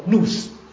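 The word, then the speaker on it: nous (νοῦς): 基督的心在新约叫做 nous 啊，我特别用中文把它表达出来，比你比较容易了解哈。那希腊文呢？啊，叫做 nous 啊，我用英文，这个英文发音跟希腊文发音是类似的啊。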